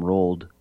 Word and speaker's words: Roald: And Roald Dahl's, Pig, it's Rule, I call him Roald because, you know, more of a phonetic pronunciation.